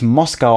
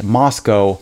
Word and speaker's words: Moscow: Bloß, es wird nicht Moskau ausgesprochen, es heißt Moscow. Das ist eine Stadt in Idaho.